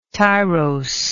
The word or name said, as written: tiros